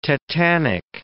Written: tetanic

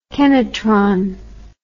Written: kenotron

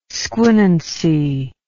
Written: squinancy

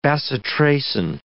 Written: bacitracin

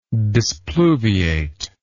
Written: displuviate

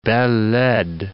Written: belle laide

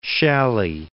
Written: Schally

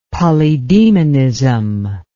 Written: polydaemonism

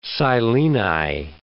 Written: sileni

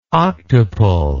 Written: octuple

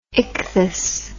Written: ichthus

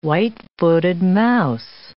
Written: white-footed mouse

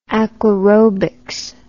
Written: aquarobics

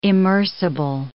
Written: immersible

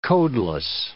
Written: codeless

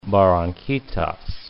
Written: Barranquitas

